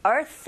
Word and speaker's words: Earth: Earth 하실 때도 TH 발음, 윗니 아랫니 사이에 혀가 살짝 긁히는 느낌.